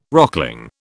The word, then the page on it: rockling: British English